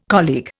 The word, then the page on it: colleague: Indian